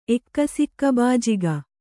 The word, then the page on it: ಎಕ್ಕಸಿಕ್ಕಬಾಜಿಗ: Kannada